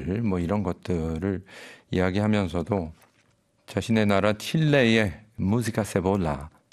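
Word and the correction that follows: cebolla: 세보야군요. 발음이 세보야 같은 이런 신파 발라드 그리고 엔카 여기가 독특하죠.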